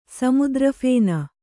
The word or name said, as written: ಸಮುದ್ರಫೇನ